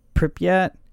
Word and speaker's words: Pripyat: Even those in the nearby town of Pripyat, yeah, that's how you say it,